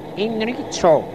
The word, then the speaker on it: Enrico: Ja, hier steht doch Enrico. – Ja, das heißt aber Enrico. C spricht man wie K im Italienischen. Jedenfalls bei diesem Wort.